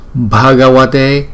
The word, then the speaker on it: Bhagavate: Thì hầu hết các cái chữ màu đỏ này là có chữ B và chữ H, là chúng ta đọc là B, B. Tại vì chữ B không á thì chúng ta đọc là B. Còn chữ B mà có chữ H thì chúng ta đưa cái hơi nhiều ra. B, B. Namo Bhagavate, Namo Bhagavate, Namo Bhagavate.